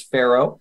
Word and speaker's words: Farrow: So my last name is Farrow. It's spelled F A R R O W. It's F with an arrow on it.